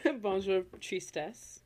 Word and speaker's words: Bonjour Tristesse: Bonjour Tristesse.